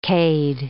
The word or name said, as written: cade